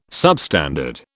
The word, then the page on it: substandard: British English